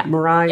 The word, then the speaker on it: Mirai: And then this, is it pronounced Mirai? Is that correct? – Christina, do you know? – Yeah. – Mirai? – Yeah, I believe so.